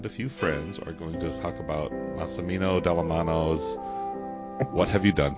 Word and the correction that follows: Massimo: I hope I got his name right. I didn't even have it sitting in front of me. Yes, Massimo Dallamano. So I think I threw an extra syllable in there.